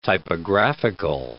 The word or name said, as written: typographical